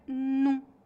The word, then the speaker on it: não: Observe que quando pronunciamos a palavra não sozinha, ela deve ser pronunciada claramente. Mas quando pronunciamos essa palavra antes de um verbo, normalmente a palavra não é reduzida para num, pronunciado rapidamente.